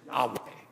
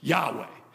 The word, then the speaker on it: Yahweh: That name in Hebrew is basically four letters. YHWH. In English, we pronounce it Yahweh. Yahweh.